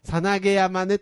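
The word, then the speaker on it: 猿投山: さなげ山ね、とにかく。 – さなげ山、猿が投げると書いてさなげ山って書く、読むんだけど。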